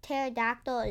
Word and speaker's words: pterodactyl: Yeah, the P is silent. – Because the pterodactyl is P-T-E-R-O-D-A-C-T-Y.